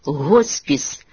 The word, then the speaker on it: госпіс: Чистою українською мовою слід писати і вимовляти «госпіс», як і в слові «госпіталь».